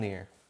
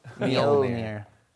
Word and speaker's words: Mjolnir: Someone in the chat told me to say mule. And then near, and put them together, and no one will guess you're getting it wrong. – Mjolnir. – Mjolnir.